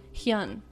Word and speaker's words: Hyun: Pronouncing it right? Pronounce it. Hyun. There you go. Just pronounce it the way it's spelled, I guess.